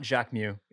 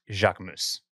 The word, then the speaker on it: Jacquemus: But yeah, it's not Jacques Mew, it's Jacques Mousse.